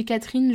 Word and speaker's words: Catrin: Oh là là, moi je le disais Catherine, parce qu'en fait ça s'écrit C-A-T-R-I-N mais j'avais pas capté que c'était Catherine. Waouh Bref, c'est l'histoire de Catherine, mais écrit C-A-T-R-I-N, donc Catherine.